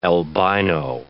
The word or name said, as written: albino